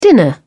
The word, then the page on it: dinner: British English